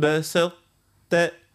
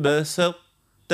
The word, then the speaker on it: بسطت: هنا كي باش نجيو ننطقوا هذايا لئن بسطت، يعني نركزوا على الطاء من الاول، نعطيه القوه لكل الطاء، ثم نمشيوا للتاء. لئن بسطت الي بسطت بسطت.